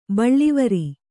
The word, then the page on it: ಬಳ್ಳಿವರಿ: Kannada